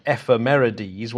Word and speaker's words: ephemerides: So I think one of the early let's go with the silly pronunciation, ephemerides, one of the earliest examples was from Babylonia, from first millennia BC.